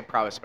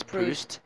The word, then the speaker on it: Proust: I know some people say Proust, but I'm pretty sure the English pronunciation is Proust.